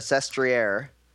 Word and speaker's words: Sestriere: And, gentlemen, yesterday's stage to Sestriere was very good. – Pretty amazing. First off, kudos to myself for pronouncing that correctly.